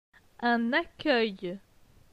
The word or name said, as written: Un accueil